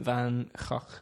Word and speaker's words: Van Gogh: Vincent Van Gogh, which is the Dutch pronunciation.